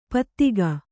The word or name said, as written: ಪಥಕ